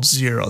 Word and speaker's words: Xero: Now, we personally love a cloud-based accounting software called Xero. That's spelled X-E-R-O.